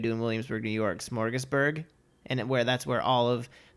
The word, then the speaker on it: Smorgasburg: We're going to head to the Los Angeles Smorgasbord Festival. – That is not how you say it. – I hate the word smorgasbord. – That's not what it's called. – It's the LA Smorgasbord. – Smorgasburg.